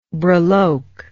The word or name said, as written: breloque